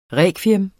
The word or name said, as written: rekviem